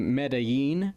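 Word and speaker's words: Medellín: So her and her mother, whose name was Anna Black, moved to Medellin, Colombia. I hope I pronounced that correctly. – Nailed it.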